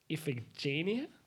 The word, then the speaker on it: Iphigenia: And I don't know if I'm pronouncing her name right. It's Iphigenia. – Wow. – Yeah. – Sounds right.